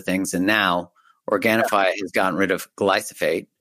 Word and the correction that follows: glyphosate: Yeah, glyphosate. I know. When I first started saying this, I said it every time. My team was like, hey, this is how you say it.